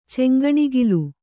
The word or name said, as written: ಚಂಗಣಿಗಿಲು